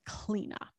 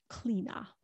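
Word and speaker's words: Clíodhna: and her name was Cleina. That's how you pronounce it. It's not spelled that way, but or the way you would imagine it was spelled, but it's pronounced Cleina.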